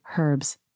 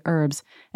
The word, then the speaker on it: herbs: Now, I have been called out because I used to say herbs, because I just like to pronounce that H. I don't like that there's a silent H there. And that is the way you can pronounce it in Europe. They say herbs. In America, most people say herbs.